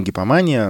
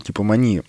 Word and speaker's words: гипомания: И когда в полярно-аффективном расстройстве, так, так называемая, наступает гипом, гипомания – не гипомания, она а правильно произносит гипомания, то, например, поэт может залпом написать много великих стихотворений.